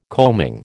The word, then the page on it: coaming: American English